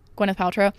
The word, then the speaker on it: Gwyneth Paltrow: Gwyneth Paltrow.